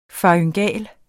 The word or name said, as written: faryngal